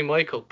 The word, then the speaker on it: Maikl: It's actually Maikl, but it's not spelled like Maikl. It's spelled M-A-I-K-L, which is even fucking cooler. – That's swag. That's swag. I respect it. – M-A-I-K-L.